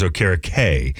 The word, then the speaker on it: Okereke: I feel like it was never, I never heard anyone say Okira Key until we signed him. Always thought it was Okira K. Okira K is the pronunciation that I'm familiar with, and when I looked up his name, that's what sounds right. Having watched him in the NFL, I'm like, yeah, that's Bobby Okereke. It's K.